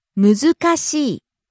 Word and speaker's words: 難しい: 難しい。 – Difficult. – むずかしい。難しい。 – Again, rising on the second syllable, ず, and coming down on the last syllable, い。